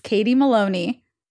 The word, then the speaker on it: Katie Maloney: Um, today, my amazing guest is Katie Maloney.